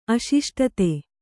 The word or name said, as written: ಅಶಿಷ್ಟತೆ